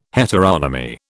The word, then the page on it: heteronomy: British English